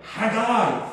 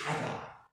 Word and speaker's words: Haggai: Haggai, that's how you should pronounce his name. I know you don't like that. We like Haggai, don't we? But really it's Haggai. That's his name.